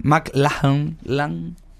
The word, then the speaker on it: MacLachlan: Ahora voy a tratar de pronunciarlo bien. MacLachlan.